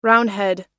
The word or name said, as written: roundhead